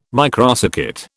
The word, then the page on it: microcircuit: British English